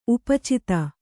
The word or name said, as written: ಉಪಚಿತ